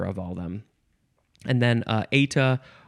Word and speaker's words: eta: This is, it's eta. Eta, that's how it's pronounced. But in Britain, and I'm not in Britain, but in Britain, they pronounce it eta, eta, no, eta or eta, and it is spelled E-T-A.